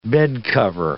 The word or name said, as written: bedcover